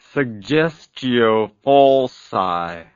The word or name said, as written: suggestio falsi